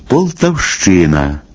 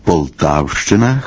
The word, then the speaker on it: Полтавщина: Нерідко чуємо «Полтавщина», «Харківщина», замість нормативного наголошування «Полтавщина», «Харківщина».